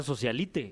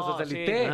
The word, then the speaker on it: socialite: Yo, estoy, yo decía socialite. – No, socialite.